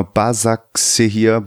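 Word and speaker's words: Başakşehir: Ich weiß nicht, ob ich es richtig ausspreche. Bekashir Istanbul oder so, der Erdogan-Verein. – Genau, Basaksehir, ja.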